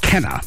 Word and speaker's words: Kenner: You know, Kenna, even though it's spelled Kenner, yes, the New Orleans accent, Kenna. And Metri. Mm-hmm. You know, it's not Metri, it's not Kenner.